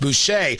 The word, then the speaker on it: Boushey: It's when you hear from White House officials, like I was just sharing, that's Heather Boushey, B-O-U-S-H-E-Y, maybe pronounced Boucher, part of the White House Council of Economic Advisors, saying that there is this wiggle room.